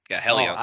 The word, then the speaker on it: Helios: Yeah, Helios. Oh, I've heard of that. – Yeah. – Oh, the way they I've heard it pronounced with the H sound.